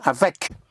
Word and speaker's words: avec: The C, like in avec, for with, avec moi, with me, avec vous, with you, avec. So C, like in avec, yes? Or like the word for bag is sac, S-A-C, sac, yes.